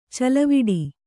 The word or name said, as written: ಚಲವಿಡಿ